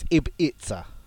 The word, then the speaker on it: Ibiza: So Ibiza. It's it's hard to pronounce. It's Ibiza.